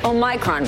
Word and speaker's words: Omicron: The rapidly spreading Omicron variant. The Omicron variant. – So, which is right, Canada, turns out they all are. Those are all acceptable ways to pronounce Omicron.